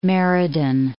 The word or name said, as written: Meriden